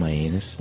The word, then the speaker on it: Mianus: There's a town named Mianus? – There is.